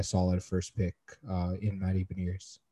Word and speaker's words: Beniers: I think on TV they pronounce his name Matthew Beniers, so I think I'm gonna go with that. So it sort of looks like a French name, but it's like pronounced in like an English way. So Matthew Beniers, yeah, he looks really solid.